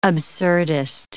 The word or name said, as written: absurdist